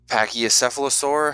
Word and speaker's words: Pachycephalosaur: since it's based off the Pachycephalosaur, I think is how you say it. – Yes, that's it.